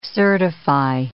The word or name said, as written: certify